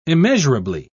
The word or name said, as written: immeasurably